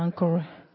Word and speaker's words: Anchorage: Ah, Anchorage.